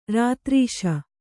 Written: ರತಿರಸ

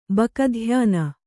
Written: ಬಕಧ್ಯಾನ

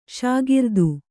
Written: ಷಾಗಿರ್ದು

